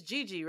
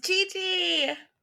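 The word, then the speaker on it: Jiji: Would you say, I always want to say it a different way but because of how it's spelled, but I think this is how you say it. It's Gigi, right? – Yes, Gigi!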